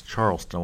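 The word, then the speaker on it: Charlston: Yes, Charleston. I want to make sure I said it right.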